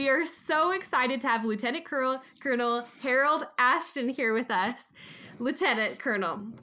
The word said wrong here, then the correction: Alston: Let me correct you one thing. We pronounce it Alston. – Oh, Alston. My goodness, I'm so glad you corrected me with that. That's important. Lieutenant Colonel Harold Alston.